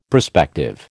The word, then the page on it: prospective: American English